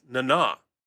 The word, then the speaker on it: Nana: by the way, and we've been saying it wrong this whole time. It's Nana, not Nana. Nana visitor. Okay.